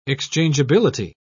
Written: exchangeability

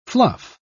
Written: fluff

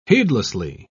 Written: heedlessly

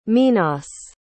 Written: minas

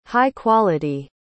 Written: high-quality

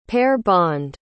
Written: pair-bond

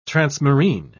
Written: transmarine

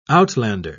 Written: outlander

